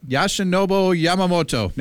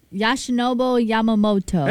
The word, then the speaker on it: Yoshinobu Yamamoto: Logan Henderson versus Yoshinobu Yamamoto. – You probably said it right. – Wait, who is that? – Yoshinobu Yamamoto. – Yoshinobu Yamamoto.